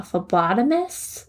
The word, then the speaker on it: phlebotomist: He even got a job being a, probably going to jack up the word, a phlebotomist. Is that how you pronounce it correctly? – Yeah.